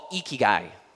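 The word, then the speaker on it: ikigai (生き甲斐): There is this Japanese word, this Japanese concept that's called ikigai, ikigai.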